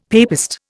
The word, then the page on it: papist: Indian